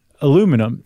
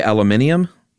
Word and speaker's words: aluminium: But we spell it aluminum in the U.S. and in Canada. Apparently, the rest of the English-speaking world spells it aluminium. There's that extra syllable spelled out. – Oh, they say aluminium? – Yeah. – Oh, okay. I didn't know that. I thought it was just aluminium. – Right, but they're really saying aluminium, but they're British.